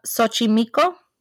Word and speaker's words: Xochimilco: Isla de las Muñecas is south of Mexico City in the canals of Xochimilco, think I said that right. – Oh, I thought it was Xochimilco. – So is it Xochimilco? – I missed. Yeah. – Xochimilco. Okay.